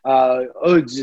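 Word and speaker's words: Łódź: Lodz is the way they pronounce it, spelled L-O-D-Z, and seen people from the boxing world from New Jersey.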